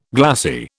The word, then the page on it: glassie: British English